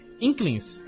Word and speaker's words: Inklings: Não, Inklings. Inklings. É? Inklings. É mudo ali, entendeu? É Klings, Klings, Inklings.